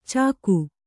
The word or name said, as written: ಚಾಕು